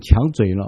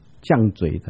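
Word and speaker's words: 强嘴: 上一回两周前，我们讲到罗马书第九章，在开头的地方我提到一个词，就是保罗跟他们说，你们怎么敢跟神抢嘴呢？后来聚会完有人纠正我说，那个应该读作犟嘴的才对。对了，就像倔强也是用强这个字哈，读作倔强。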